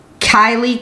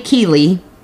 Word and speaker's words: Kiely: Well, anyways, now um, Kylie Kiely. I don't know why I want to say it, call her Kylie, cause it's how it's spelled.